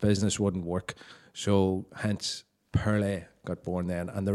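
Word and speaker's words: Pearlai: Now, Pearlay, in case you're wondering how to spell that, it's P-E-A-R-L-A-I.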